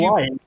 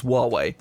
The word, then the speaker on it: Huawei: So as I understand it, it's Huawei. – How is it Huawei? It doesn't begin with a frickin' W.